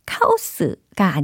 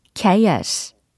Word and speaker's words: chaos: 카오스가 아니라 chaos, chaos라고 발음을 하셔야 돼요.